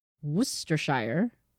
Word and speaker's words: Worcestershire: Worcestershire? Worcestershire? Go. Do I trust Siri? – Do you have something equivalent to Siri? – How do you pronounce Worcestershire? – That's pronounced Worcestershire. – Worcestershire.